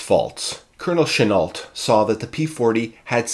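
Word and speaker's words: Chennault: Firstly, as I am recording this podcast in Montreal, Quebec, Canada, where there is a significant French population, I have always assumed that the good colonel's name was pronounced in the French manner, Chenault. However, in researching this episode, I have learned that, being an American, the colonel's family pronounced it in the Americanized way, Chenault.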